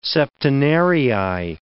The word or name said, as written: septenarii